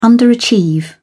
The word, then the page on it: underachieve: British English